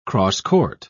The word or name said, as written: crosscourt